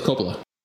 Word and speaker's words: Coppola: Nicholas Coppola. – Coppola. Playing Nicky, so he put himself twice in the credits, which is pretty funny. – Yeah, which Nicolas Coppola is his real name.